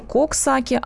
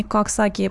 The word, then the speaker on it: Коксаки: Пожалуйста, говорите коксаки, а не каксаки. По-английски это звучит не очень. Ну, мы уточняем, конечно же, пишется коксаки через О.